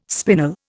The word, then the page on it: spinel: Indian